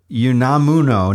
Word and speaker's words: Unamuno: FYI, the paper book that Rios is reading is Miguel de Unamuno, 1912, Tragic Sense of Life. – Yes, I keep writing that down. – Yep. – Unamuno is how I'm supposed to pronounce it. Thank you, Sean.